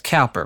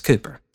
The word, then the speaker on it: Cowper: William Cooper, a lot of people think that's Cowper because of how it's spelled, but it's Cooper.